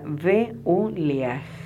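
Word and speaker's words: Beuliah: Tu ángel se llama Beuliaj y se escribe B corta E U L I A H.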